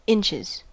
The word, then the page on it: inches: American English